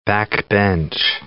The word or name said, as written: backbench